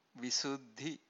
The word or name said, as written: visuddhi